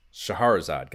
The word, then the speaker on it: Scheherazade: Here we have Super C yet again, Shadowgate, Codename Viper, the Magic of Scheherazade. – Scheherazade, goddamn it. Yeah, that's the right pronunciation.